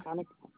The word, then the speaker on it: Konica: Monica? – Konica. – Konica. How you spell it? – K-O-N-I-C-A. – K-O-N-I-C-A.